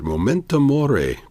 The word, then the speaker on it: Memento Mori: Momento Mori. Did I say that right? – You did. Yes.